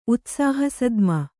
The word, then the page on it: ಉತ್ಸಾಹಸದ್ಮ: Kannada